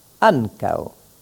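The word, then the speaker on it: ankaŭ: Le ou étant accentué, s'associe aux deux lettres qui précèdent avec lesquelles il forme alors une seule syllabe. L'accent tonique porte ainsi sur an et le mot se prononce ankao.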